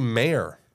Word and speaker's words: Mayer: It's actually Mayer, Oscar Mayer. – Not Oscar Mayer. – Not Mayer. But they even, like. – I could almost believe that one, though. – Yeah. – This is like a pronunciation error. – Yeah, that one can go Mayer, Oscar Mayer Wiener.